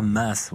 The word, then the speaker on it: Maes: C'est Clara Maas. Vous voyez, c'est ah ouais, Merci de me corriger. De la Belgique. Merci. de, chez nous, merci on de me corriger. Voilà. Eh bien, je prononcerai Maas à l'avenir.